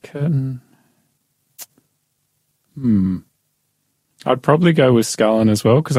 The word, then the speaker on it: Scullin: B, James Scullion. I think that's how you say it. Mm-hmm. D, John Curtin. Prime Minister was sworn on this day in 1929? Robert Menzies, James Scullion, S-C-U-L-L-I-N, Scullion, yep.